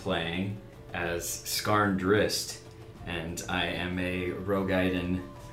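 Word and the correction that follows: Roegadyn: Rogaiden? – Or is that how you say it? – Nope. – Who cares? I don't know how you say it. Rugadin? – Whatever. Wasn't it Rugadin? – Yes. Rugadin. That's so stupid.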